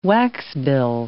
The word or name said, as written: waxbill